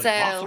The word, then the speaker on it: Voss: Voss rhymes with boss.